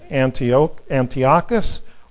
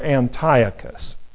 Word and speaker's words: Antiochus: Then 230 years later, it was conquered by a ruler named, two ways of pronouncing it, Antiochus or Antiochus, A-N-T-I-O-C-H-U-S.